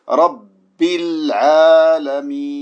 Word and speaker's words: رب العالمين: Prononce bien sur le bas, il y a une chadda, donc faut appuyer. Rabbi al-alamin.